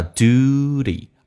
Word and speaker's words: duty: 第一个 It is 有一个连音T 说话成 D 这个发音叫 It is It is the duty 美式发音当中这个单词趋向一发 U 这个发音 U 趋向一发 U 叫 duty 而且 T 要说话成 D Duty Duty